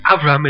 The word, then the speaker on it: Avram: Back to the story of Abraham, or more accurately, the story of Avram as it's pronounced in Hebrew.